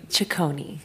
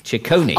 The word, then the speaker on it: Ciccone: I think, is it pronounced Ciccone? – Ciccone. – Ciccone.